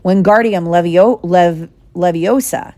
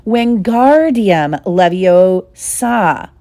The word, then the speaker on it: Wingardium Leviosa: Wingardium Leviosa, he shouted, waving his long arms like a windmill. You're saying it wrong, Harry heard Hermione snap. It's Wingardium Leviosa. Make the gar nice and long.